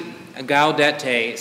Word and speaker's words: Gaudete: Good morning, and happy Gaudete Sunday. At least that is how they told me to pronounce it on Wikipedia.